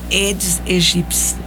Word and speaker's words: Aedes aegypti: para reduzir, né, a manifestação do Aedes, a Aedes aegypti.